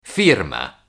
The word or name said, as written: firma